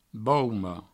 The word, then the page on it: bauma: Provençal; baumo